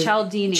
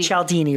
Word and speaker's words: Cialdini: Because Cialdini. – Cialdini.